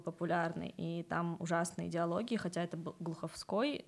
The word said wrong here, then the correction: Глуховский: но... – Глуховский. – Глуховский, да. Извините, я почему-то путаю ударение, но диалоги настолько не соответствуют возрасту персонажей, то есть, ну, они молодые там.